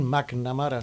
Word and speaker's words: McNamara: A ver cómo pronunciamos el apellido. – Bueno, qué sé yo. No, pero... A ver, McNamara, ¿está bien McNamara? – Sí, a veces me preguntan si es japonés.